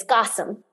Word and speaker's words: Kassam: And still you can see some of my videos and you'll notice that I pronounced my name Kasam before. Mm. And it's Gossam.